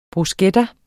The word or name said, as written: bruschetta